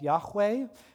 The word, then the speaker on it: Yahweh: Now, we pronounce the word more often than not nowadays as Yahweh, but really this is just the pronunciation most scholars think is probably best. We're not 100% sure. It's because ancient Hebrew has no vowels.